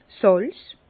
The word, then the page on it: 's Sàlz: Bas-Rhin; Herrlisheim